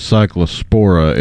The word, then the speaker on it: Cyclospora: So yeah, the parasite is Cyclospora. It causes cyclosporiasis. – Okay, Cyclospora. So I had the accent in the wrong place, which that's one of those. – I guess, wrong emphasis on the wrong syllable.